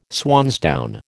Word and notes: swansdown: American English

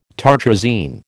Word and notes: tartrazine: American English

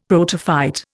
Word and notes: protophyte: Indian

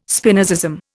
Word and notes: Spinozism: Indian

